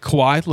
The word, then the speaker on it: Kawhi: Kawhi Leonard. – Kawhi Leonard. – Kawhi is spelled how? – Like K-A-W-H-I. – K-A-W-H. Oh, that's right. It's not Kawhi. – Yeah. Not K-A-U-I. – It's K-A-W-H-I.